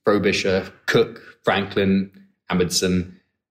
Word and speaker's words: Amundsen: So Amundsen from Norway. – Amundsen. – Yeah. Am I pronouncing it wrong? – Yeah, Amundsen.